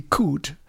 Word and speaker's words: could: Oui, If Only I Could, parce qu'on ne prononce pas le L dans le mot could. – Ah, cool. – On dit cool.